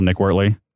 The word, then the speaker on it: Nick Wortley: And, you know, I'm on LinkedIn as well, Nick Wortley.